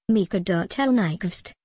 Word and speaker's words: Mikael Nyqvist: Rolf Ek Mikael Nyqvist, Swedish pronunciation, Mikael Nyqvist, November 8, 1960, June 27, 2017, was a Swedish actor.